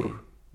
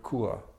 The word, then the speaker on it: Chur: Chur. Yeah. – Chur. It's spelled C-H-U-R.